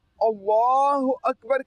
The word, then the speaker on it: अल्लाहु अकबर: तो इसका जो प्रोनाउंस है अल्लाहु अकबर है, क्या है? – अल्लाहु अकबर।